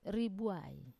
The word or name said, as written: ribuai